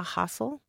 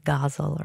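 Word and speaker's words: ghazal: When I'm working with my students on this forum, we practice that sound at the beginning, hustle, because often they'll say gazal or gazal.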